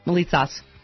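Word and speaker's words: Moulitsas: Marcos Melitzos. Did I say that right? – Melitzos.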